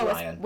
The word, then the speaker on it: Rian: Is that how you pronounce it? – Ryan. – It's just spelled Ryan. – It's Ryan. – With an I.